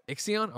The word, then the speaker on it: Ixion: Izion? How do you say this one? You think? – Is it Ixion? – Ixion. Okay. Marvel's Midnight Suns, Potion Craft, Alchemist Simulator.